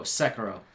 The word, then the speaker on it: Sekiro: Serico, Shadows Die Twice. – I'm sure it was good. I don't think you said it right. Sekiro? – Oh, Sekiro. Sorry.